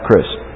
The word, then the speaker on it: Christ: Dr. Christ, which rhymes with grist, said, Dr. Grist is head of the San Jose State University Women's Studies program.